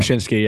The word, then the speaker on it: Wyshynski: Before you give an answer, it's like you and Greg Wyshynski. I think I said his name right. I don't know. – Wyshynski, yeah.